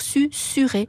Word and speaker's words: susurré: Alors, vous êtes libre, Stéphane, et Clément aussi, mais tous les dictionnaires préconisent de dire susuré.